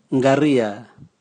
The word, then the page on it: ngaria: Raga